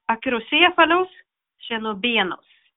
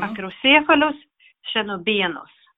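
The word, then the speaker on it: Acrocephalus schoenobaenus: Eh, Acrocephalus chenobenus. Mm. Acrocephalus chenobenus. I första fallet blir betoningen på tredje stavelsefruset, slutet, acrocephalus och i bestämningen till det, Chenobenos, och andra stavelsen från slutet, näst sista stavelsen.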